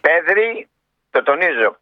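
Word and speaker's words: Pedri: Πέδρη, το τονίζω, Πέδρη, Κομπαρσί που είναι 18-19 χρόνο, πόσο είναι? – 19 ο Κομπαρσί.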